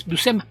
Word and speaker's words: Buscema: También la pareja de hermanos artistas, el legendario John Buscema y su hermano. La U es un poquito más larga y suave, como si llevaran ahí al frente, es Buscema. Pensando también en que el origen del apellido hace que se pronuncie un poquito distinto.